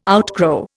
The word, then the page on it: outgrow: Indian